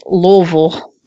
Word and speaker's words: Louisville: Don't forget, it's Louisville.